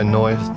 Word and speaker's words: Neu: Next on the list of tracks that we're going to play is by a band called Noi. Did I pronounce that right? – I think so, yeah, Noi. That's about, I'm talking bollocks again, but yes, I think that was the right pronunciation.